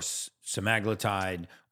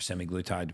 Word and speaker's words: semaglutide: And, you know, a year and a half ago-ish, Wegovy, Ozempic, Mounjaro, or semaglutide, or semiglutide, depending on how you say it, and tirzepatide hit the market.